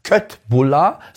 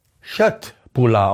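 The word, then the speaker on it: köttbullar: Und die Fleischbällchen bei IKEA heißen übrigens nicht Köttbulla, sondern es wird Schöttbulla ausgesprochen.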